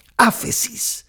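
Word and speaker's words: áfesis (ἄφεσις): La palabra remisión es una palabra griega que es, se pronuncia así, áfesis.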